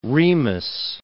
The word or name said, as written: Remus